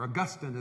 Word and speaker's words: Augustine: One of the great disciples of Christ, St. Augustine, or Augustine as some people pronounce his name, he was the bishop of a North African town called Hippo.